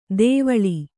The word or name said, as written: ದೇವಳಿ